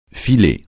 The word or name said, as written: filer